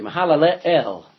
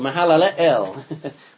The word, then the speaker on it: Mahalalel: Now, it probably would be pronounced this way, Mahalalel, because the last two letters there, El is for Elohim. So Mahalalel would be how his name is pronounced. Like hallelujah. That means praise the Lord.